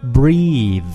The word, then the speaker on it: breath: 当动词来讲的话呢，它的发音是 breathe, breathe. 但是如果我们说把它这个词性变成名词，比如说这个人呼出的这个口气啊，它的发音叫 breath, breath,